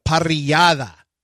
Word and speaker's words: Parrillada: And the Parrillada, which I know some of you are going to be mad that I'm saying it correctly, but it is what it is.